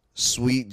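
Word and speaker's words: Suite: Jeff Joe on Facebook, Sweet Jones on Instagram, and it's spelled like Hotel Sweet. Okay, not sweet like candy.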